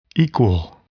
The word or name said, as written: equal